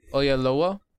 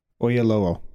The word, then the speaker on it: Oyelowo: Uh, the movie is starring George Clooney, Felicity Jones, David Ayelowo. Did I say his name right? – Uh, Oyelowo? – Yeah. Oyelowo. Sorry, David.